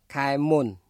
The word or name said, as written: ខែមុន